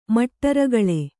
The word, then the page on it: ಮಟ್ಟರಗಳೆ: maṭṭa ragaḷe